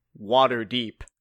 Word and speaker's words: Waterdeep: I feel like cheese is not the reason I came to Waterdeep.